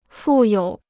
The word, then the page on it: 负有: fù yǒu